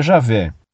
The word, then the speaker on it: Javé: Um conhecido meu, que é judeu, confirmou que a pronúncia do tetragrama em português seria Javé.